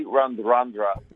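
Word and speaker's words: Radradra: Guys, can I just preface this, that Semi Radraja, and that was the pronunciation last night, as it has been through the entire World Cup, was just something else in Fiji's eventual loss to Wales.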